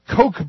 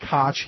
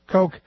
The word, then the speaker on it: Koch: Ron writes me, Rick, you've been pronouncing the Koch brothers' name like former mayor of New York. This is incorrect. I was saying Koch. He's saying it's Koch. K-O-C-H is pronounced like the soft drink, he says. Coke, sort of like David Boner, is Boehner is pronounced Boehner, as he spells out.